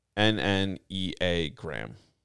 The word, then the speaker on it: Enneagram: I can't ever pronounce it right, and Ariel will kill me for it. But it's Enneagram.